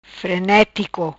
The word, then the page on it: Frenetico: patua